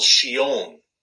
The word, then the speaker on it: Chillon: And one of the comments that I got, I don't know if you got it or not, it's pronounced Castle Chillon.